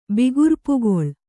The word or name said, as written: ಬಿಗುರ್ಪುಗೊಳ್